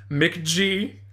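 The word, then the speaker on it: McG: I was just informed by Duncan that you pronounce the director's name as McGee, which I think is an excellent name.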